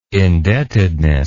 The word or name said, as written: indebtedness